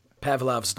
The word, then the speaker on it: Pavlov: And then, And then they hit it, and you're like, you know, like Pavlov's dog. Did I say that right? – Pavlov's dog.